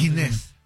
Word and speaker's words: Ginés: Pero se pronuncia Ginés.